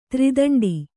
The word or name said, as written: ದೋರ್ದಂಡ